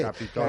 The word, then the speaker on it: Capitol: Capitol, que tiene el acento además que tiene el Capitolio, Capitolium romano. De modo que no está mal el acento en Capitol.